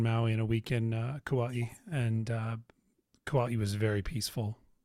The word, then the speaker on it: Kauai: Kauai or Kauai. I like Kauai. That's the more legit pronunciation.